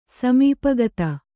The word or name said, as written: ಸಮೀಪಗತ